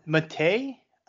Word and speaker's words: Matej: M-A-T-E-J. Is it Matej Penaz? – Matej, I believe it is. – Matej. Okay, that's right. Yeah, Matej.